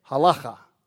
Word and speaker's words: halacha: Significantly, this is in contrast, this is for you, Eric, to the Jewish halacha, or halacha. That's how they say it, halacha.